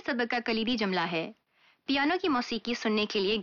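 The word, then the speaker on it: پیانو، (ピアノ): پیانو، یہ پیانو کا جاپانی تلفظ ہے،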